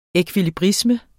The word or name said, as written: ekvilibrisme